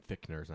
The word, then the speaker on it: Fichtner: William Finchner. – Fichtner? – Fichtner, whatever. – Okay. – Is that how you say his name? – I think it's Fichtner. – I don't know. It's spelled F-I-C-H-T-N-E-R.